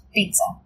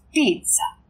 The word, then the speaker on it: pizza: In Italiano, we pronounce everything. If there is two consonants, we pronounce them both. Not pizza. It's pizza.